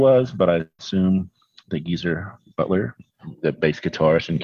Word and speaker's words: GZR: GZR or Geezer, as I guess it's actually supposed to be pronounced,